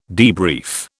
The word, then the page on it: debrief: British English